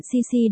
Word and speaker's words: Cici: Chú thích, 1, 2, 3, chỗ này tên của bạn Cici đọc đúng như chữ C trong tiếng Anh vậy.